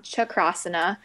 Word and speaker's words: Chakrasana: And anyway, they were just like explaining it because the Sanskrit word for wheel pose is shakrasana. And so chakra, sorry, I said that wrong. Chakrasana.